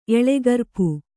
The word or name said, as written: ಎಳೆಗರ್ಪು